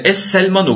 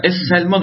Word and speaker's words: Es Selman: El Selman yani Es Selman okunur. El yazılır da Es Selman okunur.